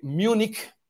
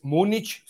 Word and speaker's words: Múnich: Pero la película que les quiero recomendar el día de hoy es bien interesante y se titula, así se titula, eh, Munich, si lo pronunciamos en inglés, Múnich, si lo pronunciamos en español, y München, si lo pronunciamos en alemán.